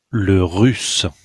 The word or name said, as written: le russe